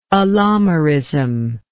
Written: allomeric

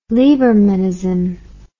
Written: libermanism

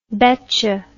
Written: becha

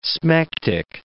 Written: smectic

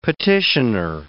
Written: petitioner

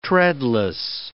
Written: treadless

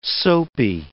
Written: soapy

